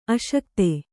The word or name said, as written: ಅಶಕ್ತ